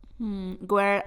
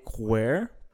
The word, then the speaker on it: where: Where are you from? – Tú dijiste where, pero la pronunciación es where.